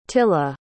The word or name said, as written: tilla